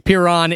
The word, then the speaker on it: Piran: And you were right with a short, like, two syllables. So we're very close. Piran is the little seaside town, coastal town in Slovenia.